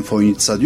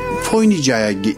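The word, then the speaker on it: Fojnica: Fakat gelen misafirlerin hepsi o C'yi S olduğunu bilmiyor, TS olduğunu bilmiyor ve Foynica'ya işte gelmekten şeref duydum gibi şeyler zikrediyor. Merkeze bir yazı yazdım. Dedim ki Foynici olarak yazılan kelime telaffuzu Foynitsa şeklindedir, düzeltilmesi.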